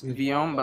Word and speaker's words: Vyom: Vyom. Yeah. – Okay. – A lot of people do get it mixed up. – Though. So it's not Vyom. – It's Vyom, but some I remember.